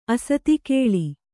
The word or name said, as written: ಅಸತಿಕೇಳಿ